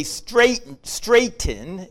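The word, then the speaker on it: Straton: Stranton, okay, rhymes with Dayton. His name, so you really say Stratton. It rhymes with the word Dayton.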